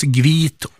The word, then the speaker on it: hvit: Det uttalas gvit och vass.